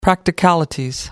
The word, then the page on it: practicalities: British English